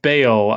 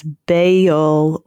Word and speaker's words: Baal: Oh, Wikipedia lists the pronunciation as Baal or Baal, but in both cases, emphasis on the first syllable, but there are two syllables in either pronunciation that are listed there. Yeah, but that A would definitely be an ah sound in the original language.